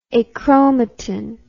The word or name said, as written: achromatin